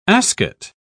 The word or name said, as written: ascot